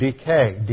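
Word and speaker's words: dike (δίκη): In Greek, it is the root word dike, D-I-K-E and eta, long, almost a long A sound, from which we get dikaiao, the verb, dikaiosune for righteousness, dikaios, justified.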